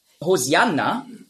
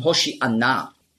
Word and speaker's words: Hoshi'anna: Hosianna ist einfach die griechische Aussprache von Hoshi'anna. Hosianna, das ist die griechische Aussprache. Weil die Griechen, die haben im Alphabet kein Sch-Laut. Nur Z. Die Hebräer schon. Auf Hebräisch sagen Hoshi'anna. Aber Griechen können das nicht.